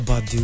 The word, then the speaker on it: Badeau: Erica Badeau.